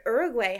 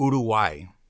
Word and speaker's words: Uruguay: Uruguay. – Uruguay. – Yeah. – Beautiful. – Yeah. – Thank you. – You're welcome. So Uruguay is not Uruguay. Wrong. It's just that's the Anglo pronunciation of it.